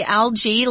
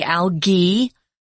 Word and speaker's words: algae: they don't say algae like we do. They say algae, with a hard G sound, so it's a little different.